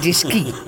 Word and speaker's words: Disky: Barbara Disky, is het niet? – Nou, met de klemtoon op key, hè? Barbara, ja. Disky.